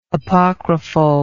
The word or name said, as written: apocryphalness